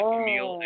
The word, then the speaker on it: Kamille: Also appearing, NWA National Champion Willie Mack, NWA Vice President David Lagana, and Camille. Did I say that right? – Okay. – K-A-M-I-L-L-E. – Yeah, Camille.